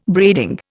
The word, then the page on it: braiding: Indian